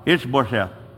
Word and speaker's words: Ish-bosheth: Say it now, Ish-bosheth.